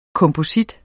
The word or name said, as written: komposit